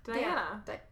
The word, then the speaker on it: Diana: Is it Deanna or Diana? – Diana. – I always thought it was Deanna because, like, why have the extra letter then if you're not going to pronounce it differently?